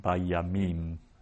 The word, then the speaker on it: bayamim: And that syllable is the syllable that gets accented. So it's under the im, mm-hmm. So it means that that's the syllable that we accent, bayamim.